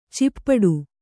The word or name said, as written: ಚಿಪ್ಪಡು